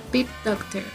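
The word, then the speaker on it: Pete Docter: Se llama Pip Doctor.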